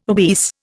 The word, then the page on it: obese: Indian